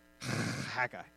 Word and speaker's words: Haggai: It's actually Haggai. Haggai. It's I just enjoy being able to make that pronunciation.